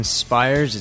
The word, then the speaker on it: Spyres: My name is Jason Spires. It's spelled S-P-Y-R-E-S.